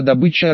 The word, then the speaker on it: добыча: Поселок производственный, все заняты на добыче русина, слово «добыча» Арсеньева произнесла по-горняцки, с ударением на первый слог, к тому же объявили ударную вахту и дежурными по поселению оставили неподготовленных детей.